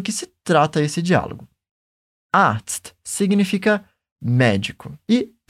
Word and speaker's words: Arzt: Primeiro, nós temos de pronunciar o som do Z, que é como se fosse um TS no português. Ts. E depois, pronunciamos um som de T. T. Por isso, falamos dessa forma. Arzt.